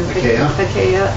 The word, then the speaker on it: Achaia: Achaia unto Christ.